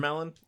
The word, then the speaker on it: Vermeillon: Is that how we agreed we're pronouncing it? Yep, it's sure. a melon. Vermelon. Vermelon. – Yeah. Vermelon.